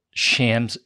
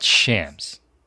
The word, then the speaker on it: Shams: You called the NBA insider Shams Charania? – Yeah, that's his name. – Yeah, bro. – It's not Shams. It's Shams. If you're covering sports, you should probably know how to say his name correctly.